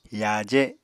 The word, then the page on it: yaaje: Apatani